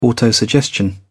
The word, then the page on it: autosuggestion: British English